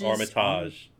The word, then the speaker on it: Armitage: Armitage. Armitage. What a stupid name. – That's how it should be pronounced. They just say it wrong in the movie.